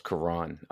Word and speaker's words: Karon: Real quick, not your fault. Everybody does it. It's all good. It's pronounced Karon.